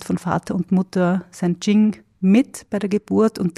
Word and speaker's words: Jing: Das Yin und das Yang, die schreibt man beide mit Y und das Ying oder Jing schreibt man mit dem, schreibt man mit J und am Schluss mit einem G, das Jing.